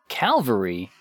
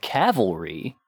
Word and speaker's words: Calvary: Now, Calvary, just Calvary, is the name of the place where Jesus was crucified. – Oh. – What people mispronounce a lot would be, like, the cavalry. – Yes. – Which is horses.